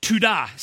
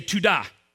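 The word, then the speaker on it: todah: The second Hebrew word today is tudah. Say tudah. It's spelled toda, but it's pronounced tuda.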